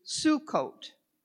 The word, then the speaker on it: Sukkot: And the word in Hebrew is Sukkot. And I hope I'm pronouncing that right, because your pastor took Hebrew, right? This summer, you did it. So is that the correct pronunciation, Dr. Pennington? Glad to hear it.